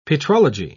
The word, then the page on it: petrology: British English